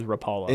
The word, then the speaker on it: Rapala: I think you're right, though. It is Rapala.